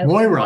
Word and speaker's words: Moira: And I mean, the whole cast, I spent a week trying to talk like Myra. I'm probably mispronouncing her name, but I Moira. Love that show. Moira.